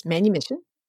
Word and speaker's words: manumission: I had never heard the term manumission. I assume that's how you pronounce it. – Yes, manumission.